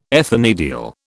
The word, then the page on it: ethanediol: British English